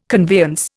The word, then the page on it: conveyance: Indian